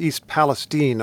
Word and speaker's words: East Palestine: It's East Palestine, Ohio.